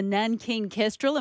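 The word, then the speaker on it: Nankeen Kestrel: So one of the birds you've been studying is the Nankin Kestrel.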